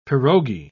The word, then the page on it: pirogi: British English